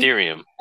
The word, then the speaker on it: Ethereum: Ethereum. – Ethereum.